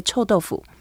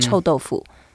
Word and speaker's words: 臭豆腐: And then to say smelly tofu, we say that properly, you say 臭豆腐. – 臭豆腐. – Yeah, if you say smelly tofu, they may not know. So you say 臭豆腐.